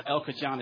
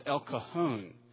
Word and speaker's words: El Cajon: And the guy there said, "No, it's not El Cajon, it's El Cajon." He said, "Because of the, here in Southern California, we pronounce our J's with the H sound because of the influence of the Spanish." He says, "El Cajon."